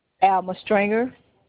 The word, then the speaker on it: Stringer: Alma Stringer. – Okay, can you spell it for me? – A-L-M-A. Stringer, S-T-R-I-N-G-E-R.